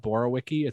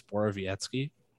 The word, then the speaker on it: Borowiecki: Um, that is how you say that name, right? It's not Borowicki, it's Borowiecki.